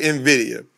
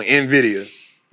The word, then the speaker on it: NVIDIA: Like, if you look in the on NVIDIA, NVIDIA, how it's pronounced, right? You know, we be saying the word all the way wrong. NVIDIA is NVIDIA, right?